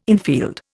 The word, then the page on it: infield: Indian